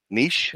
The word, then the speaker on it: niche: It's pronounced niche because it's a French word, but that's okay.